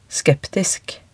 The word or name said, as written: skeptisk